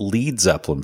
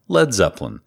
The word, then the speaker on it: Led Zeppelin: And he spelt lead LED because, and this is true, he didn't want North Americans, he didn't want them to pronounce it Lead Zeppelin, so he changed it to Led Zeppelin.